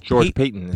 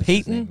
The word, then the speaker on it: Paton: We almost had a double Rick Spielman influence because Rick's right-hand man is at George Payton. – Payton? Yes. – It's spelled like Patton, though, right? – Yeah, P-A-T-O-N. – Oh, P-A-T-O-N. Okay, yeah. I thought there was a second T in there.